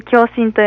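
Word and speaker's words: 響心: 響くに心で共振と読みます。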